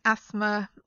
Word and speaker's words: asthma: If we pronounce it, it's asthma or asthmatic. It's not asthmatic because that would be really difficult to say. So we ignore the T and the H in that in those in this set of words.